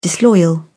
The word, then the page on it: disloyal: British English